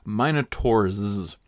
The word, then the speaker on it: Minotaur: I note that different people pronounce this in different ways. I have always said Minotaur. I have looked it up, and either Minotaur or Minotaur is acceptable, but I've always said Minotaur, so that's what I'm going to say.